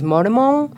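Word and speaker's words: Moromon (モルモン): For example, I served a mission for the LDS Church in Japan, and the way you say Mormon is Moromon, which rhymes with hormone in Japanese.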